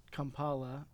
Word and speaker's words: Kampala: I was doing some research as we were talking on Kampala. Am I saying that right? – Yes.